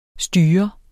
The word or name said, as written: styre